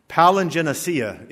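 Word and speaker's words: Palingenesia (παλιγγενεσία): Palingenesia is how it's pronounced, but it's literally spelled palingenesis.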